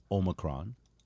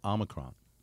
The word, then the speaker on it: Omicron: And there's two correct pronunciations. The British pronunciation is Omicron, and the Americanized pronunciation is Omicron.